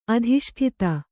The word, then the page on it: ಅಧಿಷ್ಠಿತ: adhiṣṭita